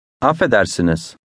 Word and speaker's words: Affedersiniz: Siniz. Is. Siniz. Eder. Edersiniz. Edersiniz. Af. Affedersiniz. Affedersiniz.